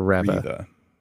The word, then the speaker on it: Reva: Reva or Reva? – Reva.